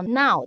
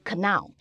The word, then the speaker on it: know: 对，那我们说最简单的一个字，我们说现在它叫 now，大家都知道 n o w，对那你前面加个 k，就变成知道那个字 k n o w。嗯、可是如果用自然发音法，小朋友他可能就会念成 canal。可是你知道，知道这个英文叫做 no，对不对？